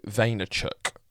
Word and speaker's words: Vaynerchuk: Gary Vaynerchuk.